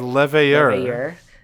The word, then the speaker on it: Leveilleur: And the people who were primarily responsible for doing that were the group of Archons that we just mentioned under Louis-Soit Lavalier and his... – That is not how you say his last name. – How do you say that last name? I never say it right. – It's Leveilleur. Leveilleur.